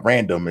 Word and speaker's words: RNDM: Lastly, I would say 2015's record for me, Random, it's spelled R-N-D-M,